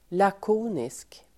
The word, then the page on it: lakonisk: [lak'o:nisk]